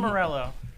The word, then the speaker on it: Morello: I want to make sure I said the name right. Mor- Morale. Morelli. Tom Morello.